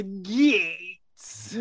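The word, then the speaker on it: Gaetz: How you pronounce it? – Gates. That's how you gotta say it.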